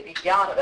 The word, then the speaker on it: Vijnana: Now, it's, okay, Vijnana, that's how you pronounce it generally. The Sanskrit purists might say Vijnana, but no one in India says that.